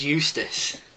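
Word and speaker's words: Eustice: I think his name rhymes with useless. Is it George Eustace? – Yeah.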